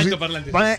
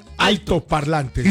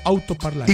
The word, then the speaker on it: altoparlante: Altoparlantes. – ¿Sí? Escuchó la frase. – No son autos, son altoparlantes. – ¿Y usted qué dijo? – Altoparlante. – ¿Y cómo es? – Y usted dijo autoparlante.